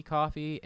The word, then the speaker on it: Ko-fi: It's spelled like Ko-fi, but I think it's supposed to be pronounced coffee.